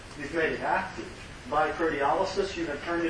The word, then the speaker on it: proteolysis: Proteolysis, proteolysis, to say it slowly, proteolysis, or proteolysis is how it's often pronounced.